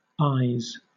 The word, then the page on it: ayes: British English